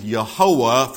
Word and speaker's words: Jehovah: And so they took the vowels from Adonai and put them under the YHWH and came up with Yehovah, from which we get Jehovah.